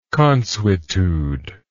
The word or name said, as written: consuetude